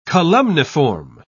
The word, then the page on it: columniform: British English